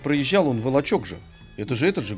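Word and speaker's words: Волочёк: И вот как раз Вышний Волочек был одним из населенных пунктов. – А вы сейчас правильно говорите «Волочек»? – Потому что Вышний Волочек, Волок. Потому что Волок.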